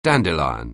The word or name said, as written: dandelion